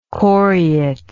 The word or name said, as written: coryate